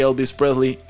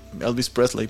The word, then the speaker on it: Elvis Presley: pero al igual que Elvis Presley en Elvis Presley, perdón, eh, aquí me pasé de lance con mi pronunciación, en 1958, probablemente decidió que esquivar el reclutamiento no lo ganaría o no le ganaría el cariño de sus fanáticos.